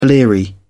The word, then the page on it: bleary: British English